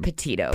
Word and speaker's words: Petito: Petito.